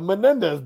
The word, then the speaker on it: Menendez: The Menendez brothers.